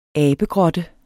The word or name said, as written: abegrotte